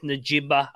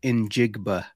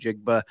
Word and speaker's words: Njigba: So I saw this pronunciation guide thing that was allegedly from the actual NFL draft, and they said it was Njigba.